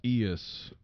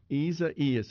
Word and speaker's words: Isaias: Isaias. – Isaias. That's right. It's got an extra syllable in there. Gee whiz. On the ceasefire text line. – The emphasis is on the second E sound.